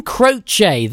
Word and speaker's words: Croce: Yeah, that song by Jim Croce. That's the correct pronunciation. Yes, Chris up in Aberystwyth has confirmed that for me.